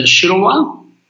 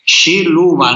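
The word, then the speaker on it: Šiluva: Bueno, y es muy conocida esta devoción a la Santísima Virgen de Shiluba. Shiluba, no sé si lo pronuncio bien. Sí, parecido.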